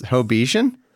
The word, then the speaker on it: Hobbesian: Is it pronounced Hobbesian? – I have often heard it that way. – Oh, wow. – You can say Hobbesian as well.